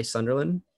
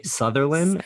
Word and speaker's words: Sunderland: at Ty Sunderland. Ty S U N D E R L A N D, not Ty Sutherland, as some that was a big pronounce thing.